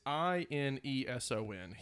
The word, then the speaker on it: Ineson: It's Ineson.